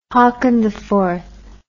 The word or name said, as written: haakoniv